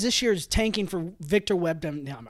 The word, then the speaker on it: Wembanyama: I just don't think – Say his name real quick. We got it. – Victor Wembanyama. – Thank you. We got to correct that.